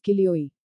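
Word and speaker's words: kilioi (χίλιοι): The story begins with chilioi, which is the Greek word for thousand. It is pronounced as kilioi.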